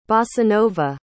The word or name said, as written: bossanova